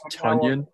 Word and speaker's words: Tonyan: It's pronounced Tonyan.